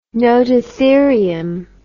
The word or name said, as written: nototherium